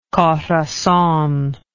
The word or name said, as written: Corazon